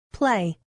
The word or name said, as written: play